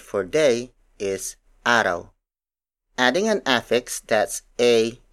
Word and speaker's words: araw: An example of a root word is araw. Araw. Araw. Araw is spelled as A-R-A-W.